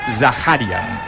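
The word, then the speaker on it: Zakharian: He escoltat, per cert, eh, avui que el, encara que s'escriu amb KH, oi que Zakarian s'escriu amb KH? K, sí ell ha dit que es pronuncia Zaharian.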